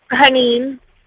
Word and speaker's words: Hanin: Hanin.